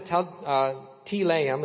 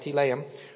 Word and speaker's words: Telaim: And so verse 4, so Saul gathered the people together and numbered them in Telaim, is how you say that, Telaim, 200,000 foot soldiers and 10,000 men of Judah.